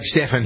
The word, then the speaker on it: Stephan: I'm Doug Stephan here on the Good Day program. S-T-E-P-H-A-N. Some people spell it J-S-T-E-V-E-N, like it was Stephen.